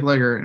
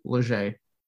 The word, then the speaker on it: Leger: Like, it's spelled L-E-G-R. It looks like Legger, and all it is Leger.